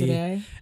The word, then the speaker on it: Cassaday: It's not Cassidy, it's Cassaday, John Cassaday. – Oh, okay. – That's the way it's pronounced.